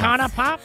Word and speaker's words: Icona Pop: How do you say that? Iona Pop. – I think it's Icona. – Icona Pop.